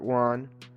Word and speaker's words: Wone: So in the 1990s, Robert Wone rhymes with lawn, so.